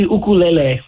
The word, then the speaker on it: ukulele: You said ukulele. Is that the proper pronunciation? Have we all been saying that word wrong for our entire life? – Well, there's two meanings, like uku. Yeah, it would be ukulele would be the correct way to say it.